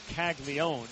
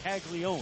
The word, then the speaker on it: Caglianone: And the nickname Jack comes from the initials of his full name, Jeffrey Allen Caglione. It's a very interesting pronunciation of his last name when you consider having known Jack since he played at Florida. It's spelled C-A-G-L-I-A-N-O-N-E, like Caglianone, but it's just Caglione.